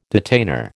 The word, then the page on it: detainer: American English